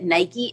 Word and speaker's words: Nike: It's actually, did you know that Nike is actually the Greek goddess and the way that you properly pronounce it is Nike?